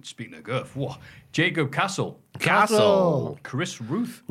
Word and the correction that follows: Routh: Routh. Oh, Rhymes with South.